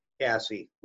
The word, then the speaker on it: CASE: And what's the role of do you pronounce it CASE for short? – CASE, we pronounce CASE.